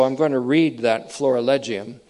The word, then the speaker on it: florilegium: The reference to the throne in Hebrews 1:8 is at the heart of what we remember we called the florigelium, or make that the florilegium. I mispronounced that earlier, and I'm doing it again. The heart of the florilegium, that's F-L-O-R-I-L-E-G-I-U-M, you'll see it in print.